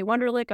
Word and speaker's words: Wunderlich: Uh, my name is Renee Wunderlich.